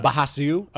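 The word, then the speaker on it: Brasil: A bahasiu? – A pine nut. – How they pronounce it there. – Bahasiu? – Bahasiu nut. Because ours are H's in Brazil.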